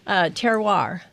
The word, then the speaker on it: terroir: terroir.